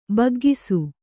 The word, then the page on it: ಬಗ್ಗಿಸು: baggisu